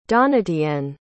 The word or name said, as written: donatienne